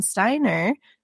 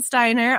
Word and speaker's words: Steiner: Today, we have an awesome author chat with none other than Logan Steiner. That is how you say your last name, right? – It is. Yes. – Logan Steiner, author of After Anne, which is a story about Lucy Maud Montgomery, the author of Anne of Green Gables.